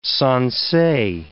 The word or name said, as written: sansei